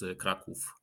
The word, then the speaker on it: Kraków: Also Krakow is already the German version. Polish is Kraków.